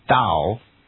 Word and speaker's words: Tao: So in the end, it's pronounced Dao and actually written Tao with a T.